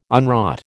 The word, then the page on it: unwrought: American English